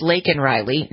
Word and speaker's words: Laken: Well, her name is Laken Riley, not Lincoln Riley.